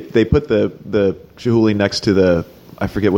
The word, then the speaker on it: Chihuly: But then, you know, I think his beef was with Chihuly. Is that how you say his name? – Yeah.